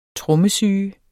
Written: trommesyge

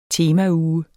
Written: temauge

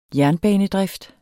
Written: jernbanedrift

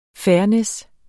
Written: fairness

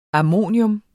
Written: ammonium

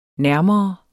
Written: nærmere

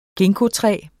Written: ginkgotræ

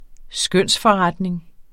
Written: skønsforretning

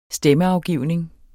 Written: STEMMEAFGIVNING

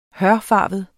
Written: hørfarvet

